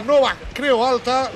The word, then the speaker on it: Nova Creu Alta: debut en casa en la nova Creu Alta, lo he dicho bien, ¿no? – Sí, no va, creo, alta.